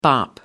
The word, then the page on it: bop: American English